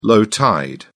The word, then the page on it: low tide: British English